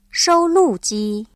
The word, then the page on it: 收录机: shōulùjī